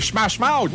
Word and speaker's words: Smash Mouth: C'est Smash Mouth.